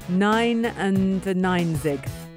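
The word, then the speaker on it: neunundneunzig: 99 is like nun. I'm going to try and say it in German. Nine and nine zig. Nine and nine zig Luftballons.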